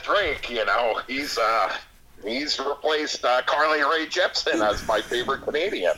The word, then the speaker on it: Carly Rae Jepsen: Besides for the fact that Justin Bieber sucks and Carly Rae Jepsen's...